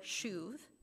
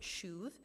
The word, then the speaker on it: shuv: And the NIV translates this Hebrew word shuv, and that's how you pronounce it, shuv, as give up.